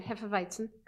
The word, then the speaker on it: hefeweizen: I got to say that again, hefeweizen, because I said the, I said it incorrectly last.